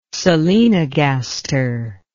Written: solenogaster

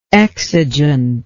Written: exigeant

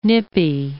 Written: nippy

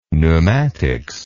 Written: pneumatics

